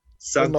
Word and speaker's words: Santé: Jason Santé oder wie spricht man deinen Nachnamen aus? – Genauso. – Santé.